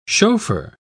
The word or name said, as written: chauffeur